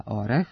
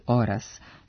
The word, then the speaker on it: orah: Imenica orah, suprotno mnogim ubeđenjima, ne poseduje normativno ispravan dublet oras, što nam potvrđuje i rečnik srpskog jezika koji beleži samo varijantu orah sa kratkouzlaznim akcentom na prvom slogu.